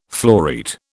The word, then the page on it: floreat: British English